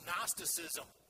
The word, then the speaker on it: Gnosticism: It's known as Gnosticism. That's spelled with a G-N, kind of like knowledge is spelled with a K-N. The G is silent, the K is silent.